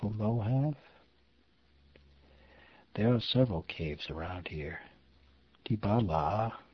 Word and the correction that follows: Dibala: Actually, let me try that again. Dibala, accent middle syllable.